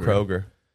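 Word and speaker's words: Kroeger: Is that his name? Chad Kroger or Kroger? – Kroger.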